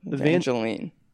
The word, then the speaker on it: Evangeline: about this film, starring Paul Rudd and Evangeline Lilly. Evang- How do you say her name? Evan- Evangeline. – Evangeline. Evangeline. Oh.